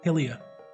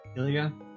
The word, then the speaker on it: Hylia: I don't know how do you pronounce that. – Hylia. – Hylia.